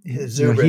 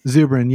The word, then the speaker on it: Zubrin: Robert Zubrin, if I can pronounce that right. – Zubrin, you know, Zubrin, yeah. I read a lot of his stuff nowadays.